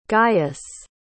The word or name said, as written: gaius